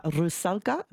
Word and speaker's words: Rusalka: He is here in Brisbane to conduct the opera Rusalka.